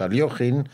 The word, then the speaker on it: Alekhine: porque no es Alekhine, sino su nombre, su pronunciación es Alekhine, hay que saber que era un deportista de alto vuelo y su concepto del ajedrez es muy importante entenderlo.